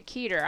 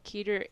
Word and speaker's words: Kedar: Well, I'm talking to Keter. I don't know if that's how you pronounce it, K-E-D-A-R. But Keter is in the networking lounge looking for companies who need artwork and digitizing services or other creative services.